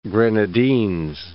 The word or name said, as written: Grenadines